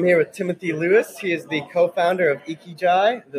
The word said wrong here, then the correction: Ikigai: Did I pronounce that right? Ikigai. Ikigai.